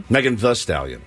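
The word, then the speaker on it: Megan Thee Stallion: It's pronounced the, not the. I thought it was pronounced the Stallion. – It's spelled Stallion. – T-H-E-E. – But it's pronounced, according to her Wikipedia page, Megan the Stallion.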